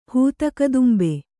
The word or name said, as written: ಹೂತಕದುಂಬೆ